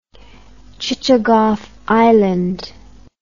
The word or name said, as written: chichagof island